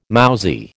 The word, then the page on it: mousy: American English